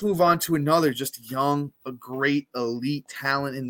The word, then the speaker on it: Jung: He kind of reminds me of a young Austin Riley. Through 71 games, 280 at-bats, Josh Jung, spelt J-U-N-J, but it is pronounced Young.